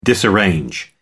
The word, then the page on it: disarrange: American English